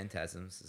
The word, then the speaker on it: Phantasms: Well, Phantasms is putting spelled out. Phonetically, Phantazmz. Yeah.